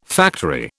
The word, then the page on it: factory: British English